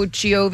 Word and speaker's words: Jovi: But you go Giovi because that's how he pronounced it growing up.